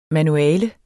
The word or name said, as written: MANUALET